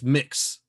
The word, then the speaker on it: Mx: You know, it's hey, it's mix. It's it's it's that's how it's pronounced.